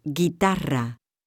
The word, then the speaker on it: guitarra: In order for the letter G to retain a hard pronunciation before these vowels, the letter U is inserted, as in portuguesa and guitarra.